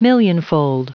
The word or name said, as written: millionfold